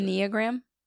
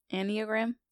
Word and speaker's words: Enneagram: Enneagram. Enneagram.